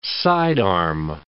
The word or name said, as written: sidearm